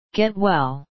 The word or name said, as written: get well